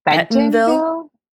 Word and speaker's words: Bentonville: Am I saying that right? – I think it's Bentonville.